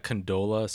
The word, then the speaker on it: Condola: I mean, I do think that Condola somehow is going to.